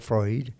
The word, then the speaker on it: Freud: Una gran influencia de todo eso fue Sigmund Freud, usted lo ve escrito como Freud, pero se pronuncia Freud,